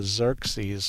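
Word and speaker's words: Xerces: There's something called the Xerxes Society. It's X-E-R-C-E-S, I believe is how they spell that. But it's pronounced like it begins with a Z, but it's an X.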